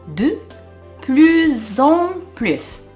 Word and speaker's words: de plus en plus: この de plus en plus は副詞表現でどんどん次第にといった意味があります。真ん中の S にリエゾンが発生するのでここはポイントですね。 de plus en plus de plus en plus